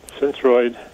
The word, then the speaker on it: Synthroid: Tell me if I'm pronouncing this right, Synthroid. – Yeah, Synthroid was,